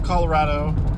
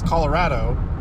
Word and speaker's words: Colorado: Natives of Colorado will usually most likely pronounce it close to how I do, as Colorado, with an ad syllable in there. Whereas everybody else pronounces it, by and large, everybody else pronounces Colorado, like an odd in the middle there.